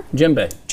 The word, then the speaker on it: djembe: Jimbe Dude. – It's a drummer. – Yeah. Does that mean drummer? – Djembe.